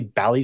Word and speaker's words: Bally: I've been saying Bally. Yeah. So my apologies. – Like Bollywood. – Yeah. – Yeah. No, it's Bally.